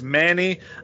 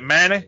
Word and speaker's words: Many: But I'm going to trust your pronunciation that it's Manny. You know, it's spelled M-A-N-Y, but, you know, down in Creole country, it's probably something like Manny.